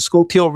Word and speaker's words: Schuylkill: And I said, you know, once we get by, I may pronounce it wrong, but I think it's the Schuylkill River. Is that the right pronunciation? – Yep.